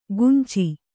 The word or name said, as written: ಕುಂಚಿ